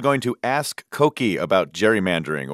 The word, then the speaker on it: gerrymandering: It really should be gerrymandering. The president had it right. Elbridge Gerry, after whom it's named, pronounced his name with a hard G.